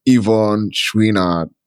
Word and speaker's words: Yvon Chouinard: Yvonne Chouinard.